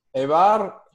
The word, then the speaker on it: Eibar: Eibar, I would say 4-0.